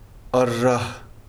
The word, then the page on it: аррагь: arrah